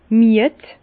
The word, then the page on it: mied: Bas-Rhin; Herrlisheim